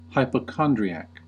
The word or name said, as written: hypochondriac